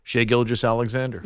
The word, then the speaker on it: Shai Gilgeous-Alexander: How'd you say his name? – Shai Gilgeous-Alexander. – Nice. Nice to hear an update anchor here at CBS Sports Radio say his name correctly.